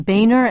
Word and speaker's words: Boehner: Well, the other direction might be taken by Roy Blunt's opposition, Ohio Congressman John Boehner, and by the way, his name is spelled B-O-E-H-N-E-R for those who've only read it and maybe not heard it, and John Shattuck of Arizona.